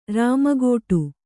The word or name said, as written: ರಾಮಗೋಟು